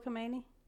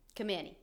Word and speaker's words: Khomeini: The leader, Ayatollah Khomeini. – Khomeini, yes. Mm-hmm. I wanted to mention, because I forgot to mention it when we were talking about the hostages. Khomeini said when they took those 66 hostages that they let 13 of them go immediately.